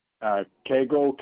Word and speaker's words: Koegel: Koegel, K-O-E-G-E-L.